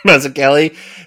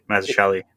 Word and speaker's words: Mazzucchelli: Mazzucchelli. There's something similar about, like... – I think you got it right, Mazzucchelli.